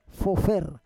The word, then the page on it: fofer: Roon